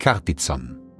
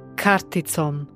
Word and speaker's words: karticom: Das C wird wie das deutsche TZ oder Z ausgesprochen. Ein Beispiel dafür ist karticom, was mit der Karte bedeutet. – Karticom.